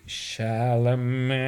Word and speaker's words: Chalamet: Men eh, Timothy Chalamet.